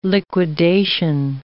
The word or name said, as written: liquidation